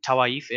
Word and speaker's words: Tawaif: So the Mughani are in Shiraz, and it's the Tawaif in Delhi, or is that the? – Yeah, yeah. – Okay, so I've got the pronunciation right.